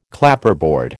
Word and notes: Clapperboard: American English

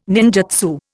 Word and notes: ninjutsu: Indian

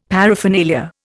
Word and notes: paraphernalia: Indian

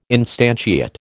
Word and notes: instantiate: American English

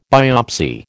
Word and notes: biopsy: American English

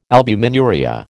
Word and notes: albuminuria: American English

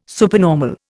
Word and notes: supernormal: Indian

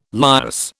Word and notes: lias: British English

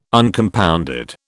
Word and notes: Uncompounded: British English

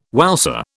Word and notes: wowser: British English

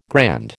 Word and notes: grand: American English

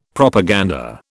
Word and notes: Propaganda: British English

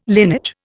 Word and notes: linage: Indian

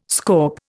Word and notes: scaup: Indian

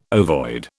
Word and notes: ovoid: British English

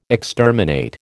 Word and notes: exterminate: American English

